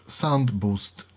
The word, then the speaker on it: SoundBoost: Dzisiaj, bo w ogóle chciałbym Państwu zaprezentować i opowiedzieć o słuchawkach firmy Overmax, słuchawkach SoundBoost 3.2.